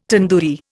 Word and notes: tandoori: Indian

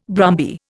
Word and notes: brumby: Indian

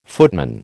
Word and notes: footman: American English

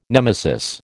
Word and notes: nemesis: American English

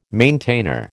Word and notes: maintainer: American English

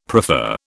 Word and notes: prefer: British English

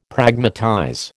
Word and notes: Pragmatize: American English

